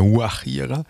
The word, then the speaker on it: Guajira: Also so spricht mit, eigentlich spricht man das eher mit H von der Guajira, mit G geschrieben aber.